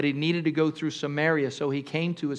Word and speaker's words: Samaria: Okay, everybody say Samaria. Say Samaria. Okay, now when I say Samaria, you say it like that. You say it sounds Samaria. Samaria. It sounds so nice, like Mary. Maria. I'm going to marry you. That's what it sounds like.